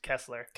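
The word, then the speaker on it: Koestler: All right, so you got Nick Cage as John Costler. Rose Byrne is Diana. – I think it's pronounced Kessler.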